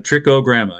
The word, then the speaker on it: Trichogramma: And so the maybe I'm saying this wrong, Trichogramma? – You got it, Trichogramma, yep.